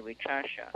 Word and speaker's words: Wicasa: It's WICASA. We say Wichasha, the red man.